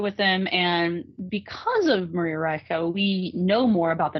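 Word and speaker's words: Reiche: and I believe there was a woman named Maria Ritchie. Well, I know there was a woman named Maria Ritchie, and I think the pronunciation, she was German, so it's Maria Reicha.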